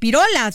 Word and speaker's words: pirola: Doctor, eh, usted como gran especialista, ¿qué nos puede decir de esta nueva variante pirola o eh, pirola? Se dice así, ¿no? – Sí, Pirola, este que dominará este invierno.